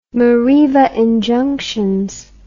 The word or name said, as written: mareva injunctions